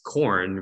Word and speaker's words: Quorn: One of the most famous form of, you know, alternative meat that's a fungi is corn, which is spelled Q-U-O-R-N, and that's in the UK.